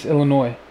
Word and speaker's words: Illinois: Why do you always pronounce that S? – Illinois. – It's Illinois.